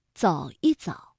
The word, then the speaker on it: 一: 再来说一说放在重叠的动词之间，这不和一怎么读呢？这两个字啊，放在中间，我们给它读轻声。走一走，说一说，谈一谈，吃不消，差不多，对不起。